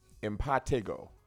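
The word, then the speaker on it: impetigo: By the way, we pronounce it wrong. It's pronounced impatigo.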